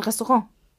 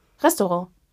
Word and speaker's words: Restaurant: Uff die betonen immer auf der letzten Silbe. Mhm. Das ist furchtbar. – Wie kommst du denn jetzt darauf? Weil du Restaurant gesagt hast. Ja. Und oh, betonen die auf der letzten oder auf der ersten Silbe? Und ich eine Freundin habe, die genau das macht. Denn die kommt daher. Mhm. Und sie sagt nicht Restaurant, sondern Restaurant. Nee, Restaurant. – Sie Restaurant. wir betonen ah, auf der ersten ah, Silbe.